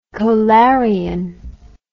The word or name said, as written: kolarian